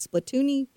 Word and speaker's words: Splatoon: Am I saying that right? Splatoon, or is it Splatoon-y? – Yes, it's Splatoon.